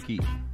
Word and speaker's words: Kyiv: That's how you say it, in Kyiv.